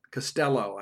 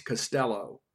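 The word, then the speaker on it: Costello: Quarterback KJ Castillo. I got to get used to that. Castello, I guess, is the right pronunciation. I've been saying, you know, Castillo. It's Castello.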